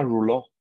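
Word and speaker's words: rouleaux: Eso se llama rouleau, se llama, se escribe roleaux con, con X al final.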